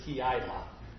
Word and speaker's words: Keilah: Thus ends the reading and hearing of God's holy word, and the town's name is Keilah. I did look it up. That is a correct pronunciation.